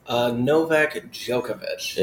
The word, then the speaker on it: Novak Djokovic: How do you say his name? – Novak Djokovic.